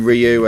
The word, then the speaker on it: Ryu: You've heard Chun-Li and Ken's name so far, but also Ryu was back, or I think actually he's meant to be pronounced as Ru, but I always called him Ryu, and that's what I'm going to go with here.